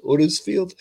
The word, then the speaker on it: Huddersfield: There's got to be is that how you say it? Huddersfield? That's how they would say it.